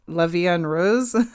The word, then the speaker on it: La Vie en Rose: And I just like the part where she sings, I don't know if I say this right. La Vie en Rose. – Yeah, that's right.